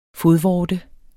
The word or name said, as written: fodvorte